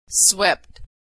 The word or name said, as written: swept